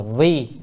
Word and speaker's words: vi: thì chữ đó nên đọc là vi, là con nay vi là làm, con nay hành Bồ Tát đạo.